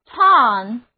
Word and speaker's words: tongue: 我们知道母语就是 mother tongue， T-O-N-G-U-E， tongue， O 字母发 A 的短音，后面是后鼻音， U-E 字母组合不发音，单词就读作 tongue，我们的舌头。